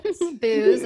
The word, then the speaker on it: Booz: She is an award-winning management consultant with 20 years of experience at companies including Disneyland, PricewaterhouseCoopers, Accenture, and Booz Allen Hamilton. I might have butchered that a little bit. – Booz. It's actually Booz Allen Hamilton. It's like the booze.